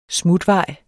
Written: SMUTVEJE

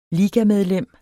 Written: ligamedlem

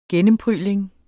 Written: gennemprygling

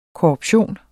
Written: korruption

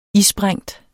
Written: isprængt

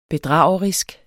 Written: bedragerisk

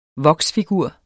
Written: voksfigur

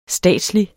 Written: statslig

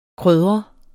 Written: krydret

